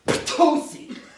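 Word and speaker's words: ptosi (πτωχοί): Poor, it's a great onomatopoetic word in Greek. It's ptosi. – Right. Do that again. – Ptosi. I'll spell that for you. That PT, right, that really, you know, if you're lecturing, you want to make sure you're close to people.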